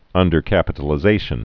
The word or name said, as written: undercapitalization